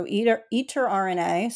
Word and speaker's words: Eterna: It's spelled E-T-E-R-N-A, so eter RNA, so thinking like RNA, which is something you might have learned in biology or genetics class.